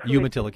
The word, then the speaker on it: Umatilla: And he has a kind of surprising ally in Stafford Hansel, who comes from Umatilla, I believe that's how it's pronounced, county. – Umatilla County, yeah.